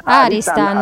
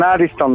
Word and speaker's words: Aristan: Aristan, accento sulla prima. – Eccola, ho già cannato. – È un neologismo, abbiamo femminilizzato una parola eccessivamente maschile per i nostri gusti, quindi ecco. Non Ariston ma Aristan.